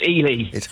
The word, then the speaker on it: Ely: All right, let's chat in Ely. Hello. – Hello, Dermot. How are you? – Very well, thanks. Is it Ely or Eli? – It's Ely.